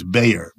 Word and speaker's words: Bayer: It's a German chemical company, pronounced in the United States Bayer.